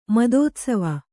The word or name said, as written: ಮದೋತ್ಸವ